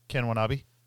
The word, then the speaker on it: Watanabe: Ken Watanabe. W-A. – It's Walanbe. – Can spell it. – W-A-L-A-N-A-B-E. – A-L-A-B-E?